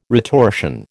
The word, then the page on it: retortion: American English